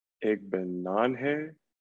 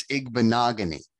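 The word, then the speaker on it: Igbinoghene: Not quite. We get right track. – Yeah, you're on the right path. We get the H sound in there pronounced a lot. You do not. It's Igbenogany. Noah Igbenogany.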